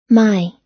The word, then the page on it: my: American English